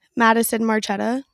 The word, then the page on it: Madison Marchetta: female